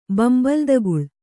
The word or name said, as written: ಬಂಬಲ್ದಗುಳ್